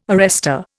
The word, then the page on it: arrester: Indian